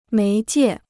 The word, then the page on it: 媒介: méi jiè